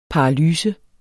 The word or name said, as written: paralyse